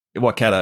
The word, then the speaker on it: Waikato: We give away our home game to go over to Gutho. Is that how you say it? – Waikato, yeah, to give the Warriors another win.